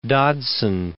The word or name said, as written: Dodgson